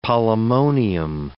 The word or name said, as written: polemonium